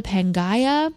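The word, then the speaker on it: Pangaia: It's called the Pangaea.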